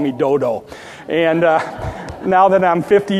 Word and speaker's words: Dodo: And then in verse 9, we read about a man named Eleazar, the son of, Brother Chuck pronounced it Dudu. I'm going to pronounce it Dodo. I'm going to make a long O instead of a short O.